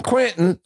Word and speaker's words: Quinton: And the guy who invented this stuff, René Quinton, Quinton is Q-U-I-N-T-O-N. I say, you know, Quinton is what the French say. Quinton is what the Northerners say. And Quentin is what the Middle Americans say.